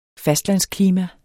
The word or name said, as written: fastlandsklima